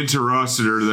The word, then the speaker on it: Interocitor: Yeah, the Intercerator. How do they pronounce it? – Interocitor. – No, the Interocitor that he has in both the films and the video, Dare to Be Stupid, and his film, UHF.